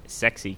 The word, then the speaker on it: SCSI: They are, according to the whatever meeting it was where they were designed, they're meant to be pronounced sexy cables.